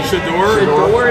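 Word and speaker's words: Shedeur: Shadour, is that it? – Yeah.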